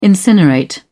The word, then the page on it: incinerate: British English